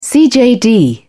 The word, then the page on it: cjd: British English